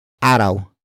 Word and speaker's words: araw: And that is araw.